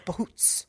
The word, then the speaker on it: bhoots: I looked it up, how to pronounce it, bhoots.